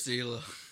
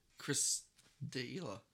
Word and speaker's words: D'Elia: But then you'll be Chris D'Elia. – I'm going to... why can't you say his name, dude? – Chris D'Elia. – D'Elia. – D'Elia. – It's spelled phonetically.